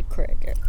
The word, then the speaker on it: Creek: I should say Crick. I'm saying it correctly.